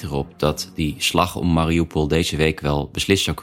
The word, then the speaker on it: Mariupol: Eigenlijk spreek je de naam Mariupol uit met een zachte L aan het eind. Dus je drukt je tong tegen je gehemelte. Le, le. Mariupol, dat je het weet.